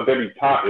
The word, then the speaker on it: debutante: And so when she saw debutante, because, you know, debutante is pronounced debutante, but it's written with an E at the end.